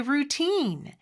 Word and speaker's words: routine: routine. 이음절의 강세가 있고 모음 소리는 길게 빼주셔야 합니다.